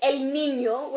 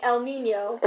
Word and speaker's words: El Niño: Like, let's say El Niño, which only people in Miami can pronounce, so everyone else is saying El Niño, if they can even get the Ñ.